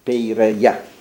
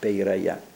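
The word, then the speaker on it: peiraia (Πειραιᾶ): E l'inizio è quello famoso: discesi ieri al Pireo, kateben sex ei peraia, peiraia, perché l'accento grave sulla ultima peiraia.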